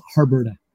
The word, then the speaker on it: Harburda: My last name is Harburda.